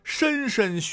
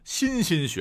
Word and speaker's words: 莘莘: 最后一个大家伙可能也很熟，不过呢经常会选错念错，这就是莘莘学子，千万不要再念成莘莘学子了。